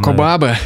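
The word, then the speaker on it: Кобо Абэ: Но вот у меня любовь появилась к книгам Абы Коба. Я все ударение ставлю неправильно там где-то. – Коба, а, Коба Абе, японский писатель, классный.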